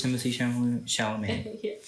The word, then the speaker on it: Chalamet: How do you pronounce his name? Chalamet? – Yeah, it is Timothée Chalamet.